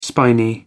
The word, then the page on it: spiny: American English